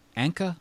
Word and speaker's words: Anka: Anka? – Oh, related to Paul. Yes. – It's pronounced the same way.